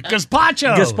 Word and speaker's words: gazpacho: Gazpacho.